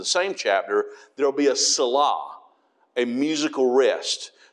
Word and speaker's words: Selah: The correct pronunciation is Selah, but some people say Selah, and they leave that H off and just make the A long.